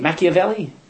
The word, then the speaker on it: Machiavelli: How do you say it? Machiavelli? Yeah, now you know his name.